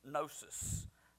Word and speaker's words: gnosis: It's G-N-O-S-I-S, the Greek word, gnosis, or gnosis, however you want to pronounce it.